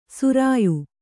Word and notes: ಶ್ರಾಯ: śrāya

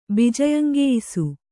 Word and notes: ಬಿಜಯಂಗೆಯ್ಸು: Kannada

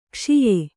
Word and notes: ಕಷಾಯ: Kannada

